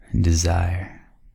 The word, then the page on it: desire: American English